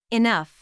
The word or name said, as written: enough